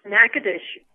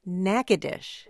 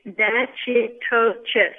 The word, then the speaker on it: Natchitoches: The name of our city is pronounced Natchitoches. – Natchitoches. – Yes. So many people get it a little bit off. When you look at it, it looks like Natchitoches.